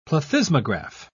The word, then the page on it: plethysmograph: British English